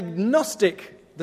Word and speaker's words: Gnostic: However, the Gnostic, the G is silent, the Gnostic Gospels from Nag Hammadi, where the G is not silent, make Jesus less, not more human.